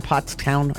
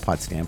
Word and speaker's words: Pottstown: That's the new headline in Pottstown, I think that's how you say it, not Pottstown, Pottstown, Pennsylvania, where they believe Danilo Cavalcanti is now, I don't know, hiding under a rock, under a tree.